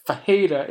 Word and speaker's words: Ferreira: Fajeda, it's Ferreira, but these Brazilian guys, like we said in episode 4, these Brazilians, they confuse us, man, because it's spelled Ferreira, but Fajeda is what they call it. There's no D in there. There's no H. There's no J. But apparently, he's Fajeda.